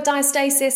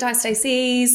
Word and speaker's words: diastasis: Yeah, so some people call it diastasis, some call it diastases, yes.